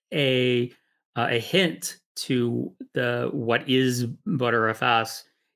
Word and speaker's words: Btrfs: So before we get into talking about ButterFS itself, how do you pronounce BTRFS or BetterFS or BTreeFS? – I pronounced ButterFS as ButterFS. – That's cool because a lot of people just have different ways of pronounce. I just call it BetterFS.